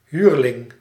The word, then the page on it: huurling: /ˈɦyr.lɪŋ/